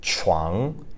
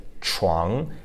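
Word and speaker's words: chuang (床): And then the second syllable, chuang, right, chuang, that's using the CH sound, which is kind of less of a clean sound and more air escaping your mouth and the rounded lips.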